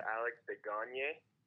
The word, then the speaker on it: Alex Desgagnes: uh, Alex Desgagnes.